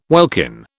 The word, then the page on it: welkin: British English